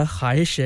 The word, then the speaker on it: ख्वाहिश: होता ख्वाहिश है इसलिए कि वाव जो है वो साकिन है, साइलेंट है, इसको पढ़ा नहीं जाता और ये फारसी का लफ्ज़ है। ठीक है जी, लिखा जाता है खे और वाव, अलिफ हे और शीन, लेकिन वाव जो है उसको पढ़ा नहीं जाता इसलिए कि वो साकिन रहेगा, साइलेंट रहेगा, तो ख्वाहिश ख्वाहिश पढ़ा जाएगा।